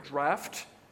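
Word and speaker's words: draught: or draft in the old British spelling. That is how you pronounce that word, draft.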